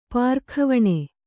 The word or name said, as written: ಪಾರಖಾವಣೆ